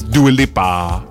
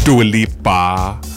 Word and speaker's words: Dua Lipa: This is Dua Lipa, Dua Lipa, Dua Lipa. I want you all to pronounce her name like that going forward. Dua Lipa.